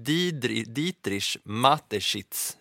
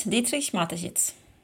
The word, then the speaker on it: Dietrich Mateschitz: är ju att Red Bulls grundare och skapare heter han Dietrich Mateschitz uttalsmässigt. – Precis, Dietrich Mateschitz.